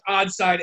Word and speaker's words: Oddside: And we get there and she sees this 4-pack of Hazel's Nuts beer from somebody, I don't know it's pronounced Oddside Ales.